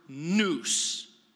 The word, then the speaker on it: nous: That word mind in the Greek language is the word nous. Not like a noose that you put around your neck, but that's the way it's pronounced.